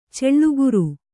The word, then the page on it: ಛಲಗಾರ: chalagāra